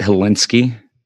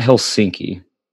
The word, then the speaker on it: Hilinski: Is that how we're pronouncing it, Hilinski? – Sure. Hilinski. – It's not Helsinki. Hilinski.